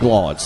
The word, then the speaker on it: Glaude: And, Jeff, I will say I appreciate the listener/viewer who sent you a text and said Glaude's last name is not pronounced like Hap Glaude over in New Orleans would have been. It's just Glaude.